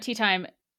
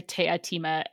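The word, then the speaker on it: Teatime: So at one point, Tea Time, his name is spelled like Tea Time, says that his name is actually pronounced te a time.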